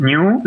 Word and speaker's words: GNU: So usually the original stem at least says it should be pronounced new, like the animal.